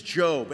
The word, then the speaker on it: Job: He is Job.